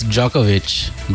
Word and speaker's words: Djokovic: It's Djokovic, by the way. The D is pronounced in this case.